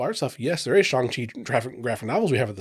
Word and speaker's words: Shang-Chi: Shang-Chi is the correct pronunciation. I've been saying it Shang-Chi forever. – Same here. – So like, sorry. Crap, I've been saying that wrong for the past 30 some years or whatever.